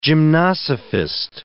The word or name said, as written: gymnosophist